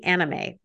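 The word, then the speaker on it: Annamae: So this, her and this slave, a black slave named Anime, and it's spelled like Anna and then M-A-E, Anime, which anime.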